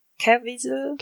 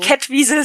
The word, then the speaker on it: Caviezel: Jim Caviezel? Genau. Ja, also nicht, nicht so. Catwiesel, sondern Genau, ohne T, ja.